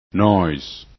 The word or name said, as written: noise